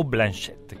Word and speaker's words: Blanchett: Che ha già preso, tra le altre cose, come non protagonista, ma noi vogliamo Kate Blanchett. O Blanchett, perché pare che si dica come Laurence Olivier con l'accento francese.